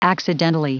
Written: accidentally

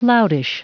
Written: loutish

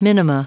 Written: minima

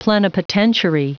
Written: plenipotentiary

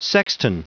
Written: sexton

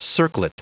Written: circlet

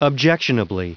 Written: objectionably

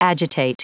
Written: agitate

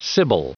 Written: sibyl

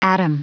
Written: atom

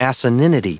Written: asininity

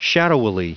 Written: shadowily

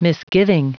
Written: misgiving

